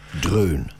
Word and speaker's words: Drön: Und der Song, den wir hörten, der hieß Songs Trön. Und so ich das jetzt richtig ausgesprochen habe, ich nehme es aber mal an. – Drön. – Drön, Songs Drön, das hat ganz schön gedröhnt.